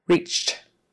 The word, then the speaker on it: reached: Reached. And that final syllable on the end, the ED, is just a T. Reached reached. It's not even its own syllable, it's just there, reached.